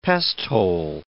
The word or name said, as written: pesthole